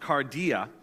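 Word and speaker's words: kardia: And in the Greek, the word heart is actually um, pronounced cardia.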